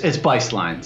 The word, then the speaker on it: Bistline: It's Bistline. So the two long eyes, German heritage, you know, just think of it that way.